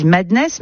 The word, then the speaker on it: MDNS: Ultra Violent, avec, je crois, on dit Madness, mais ça s'écrit MDNS.